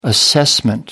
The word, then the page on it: assessment: American English